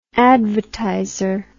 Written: advertiser

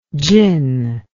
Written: tsin

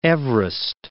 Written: Everest, Mount

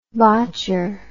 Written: botchery

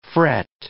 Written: fret

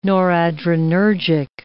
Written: noradrenergic

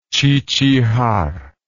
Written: chichihar

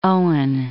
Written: Owen

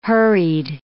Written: hurried

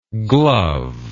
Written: glovelike

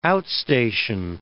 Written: outstation

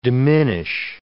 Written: diminish

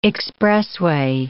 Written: expressway

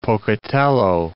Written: Pocatello

